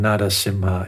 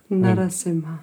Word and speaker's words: Narasimha: Just a word about the pronunciation, Narasimha. It's for the English speaking among us here, it sounds like there's a D in there, but actually Narasimha is spelled N-A-R-A. And so it's with a hard R, the pronunciation is most beneficial.